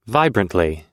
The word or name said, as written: vibrantly